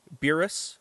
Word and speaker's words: Beerus: So the correct pronunciation for someone in America or in English would be Beerus or Beerus, or if you want to be awesome and call it Beerus or Beerusu-sama, whatever works.